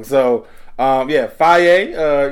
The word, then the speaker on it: Fage: Faye is spelled F-A-G-E. – Yeah, I almost said it wrong, but I was like, let me get that Faye.